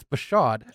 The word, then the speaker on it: Bashaud Breeland: It's Bashaud. – Just do a little prep. – It's spelled like Bashaud. – There's no R.